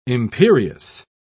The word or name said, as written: imperious